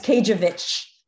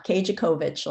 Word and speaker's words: Kajewicz: And I like that it's Kajewicz. You don't pronounce the CO in the middle, not Kajakovich, like everybody wants to say.